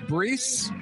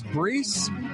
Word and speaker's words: Brees: I think it's pronounced Brees. The card says Brees, NFL quarterback, or something like that.